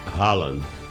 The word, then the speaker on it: Haaland: É Haaland para falar isso? Haaland? – Haaland. – Erling Haaland? É isso ou não? – Erling Haaland.